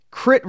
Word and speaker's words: crit: But you're more well-known for your crit racing. Am I pronouncing that right? – Yeah.